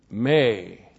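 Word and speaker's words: May (μή): M long E. May.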